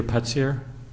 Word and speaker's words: Putzier: My name is Peter Putzier. The last name is spelled P-U-T-Z-I-E-R.